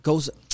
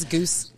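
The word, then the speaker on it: Gose: But I used to think it was goose.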